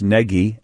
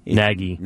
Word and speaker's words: Nagy: Because you hear it sometimes as Nagy, but it's N-A-G-Y. And I guess Nagy, is how you're supposed to pronounce it. Not Nagy and not Nagy.